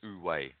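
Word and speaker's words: Wu wei: The way you pronounce it is actually Wu-Wei. And, yeah, so oh. – Wu-Wei. So it's usually, yeah, so like O-O-O. But that's how they spell it. W-E-I.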